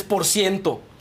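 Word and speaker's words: x100to: porque era una X100 y una TO, y es por ciento.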